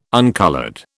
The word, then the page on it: uncoloured: British English